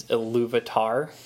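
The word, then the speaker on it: Ilúvatar: I love the way that the audiobook pronounces what all of you say is Iluvatar. Iluvatar, which is God in Tolkien's world. Elvish word for God. – Iluvatar. In the audiobook, it's Iluvata.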